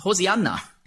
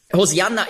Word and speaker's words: Hosianna: Also rette doch ist Hosianna. Hosianna ist eben die griechische Aussprache von Hebräisch Hoshianna. Das kommt daher, im Griechischen gibt es keinen Sch-Laut.